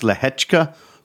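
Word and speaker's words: Lehečka: I think it's pronounced Lehechka, but it looks like Leheka.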